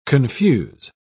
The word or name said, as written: confuse